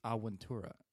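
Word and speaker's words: Avventura: No, there's two V's, so you pronounce like a W, so it's Aventura.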